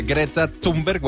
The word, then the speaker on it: Greta Thunberg: Aquesta que sona és la Greta Thunberg, ho hem de pronunciar així,